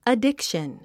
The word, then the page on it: addiction: ədíkʃən; アディクション